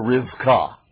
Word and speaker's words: Rivkah: And Bethuel begot Rebekah, or as you would pronounce that name in Hebrew, Rivkah.